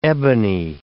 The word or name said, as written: ebony